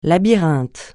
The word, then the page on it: labyrinthe: francais